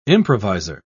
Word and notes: improviser: British English

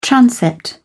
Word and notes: transept: British English

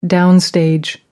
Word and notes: downstage: American English

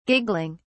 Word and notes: giggling: British English

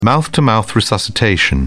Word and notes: mouth to mouth: British English

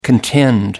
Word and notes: contend: American English

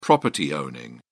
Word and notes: property-owning: British English

